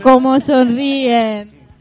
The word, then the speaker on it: Cómo sonríen: Miren Comonsorien, scusate la pessima pronuncia latinoamericana, non è proprio il mio forte. – Como sonrien.